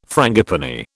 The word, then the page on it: frangipani: British English